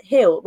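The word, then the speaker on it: Gil: We've got the new, I always say his name wrong as well, by, I think it's Brian Hill rather than Gill. I think you pronounce it Hill.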